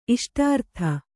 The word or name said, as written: ಇಷ್ಟಾರ್ಥ